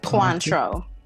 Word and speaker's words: Cointreau: Quantro.